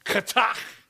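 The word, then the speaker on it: chatta'ath: The word sins, and this is how it's most often referred to and transliterated, is a Hebrew word that is spelled C-H-A-T-T-A apostrophe A-T-H. And it's an almost, just to pronounce it is, I think, conjures up the idea of sin, because you gotta go katach. You know, that's how you pronounce that word. It starts in the back of your throat and it ends in the back of your throat.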